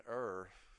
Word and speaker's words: err: Yes, because God doesn't err, just a little bit of the time. God never errs. But Vatican II did err. That's the correct way to say it, I was told. Err.